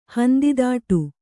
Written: ಹಂದಿದಾಟು

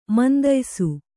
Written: ಮಂದಯ್ಸು

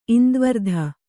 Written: ಇಂದ್ವರ್ಧ

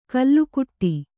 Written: ಕಲ್ಲುಕುಟ್ಟಿ

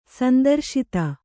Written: ಸಂತ್ರಸ್ತ

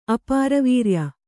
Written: ಅಪಾರವೀರ್ಯ

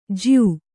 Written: ಜ್ಯೂ

